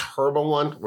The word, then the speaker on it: Herba One: It's Herba One. We're pronouncing the H and everything now.